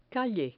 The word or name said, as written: calle